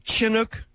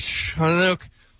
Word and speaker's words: Chinook: Is it Chinook, Montana, or Chinook, Montana? I think we arrived at somewhere in between a chuh and a shuh, so sort of Chinook, Montana.